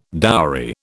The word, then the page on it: dowry: British English